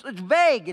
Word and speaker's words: vague: It's not tongue. It's not vague. It's vague. It's tongue. The U-E is silent.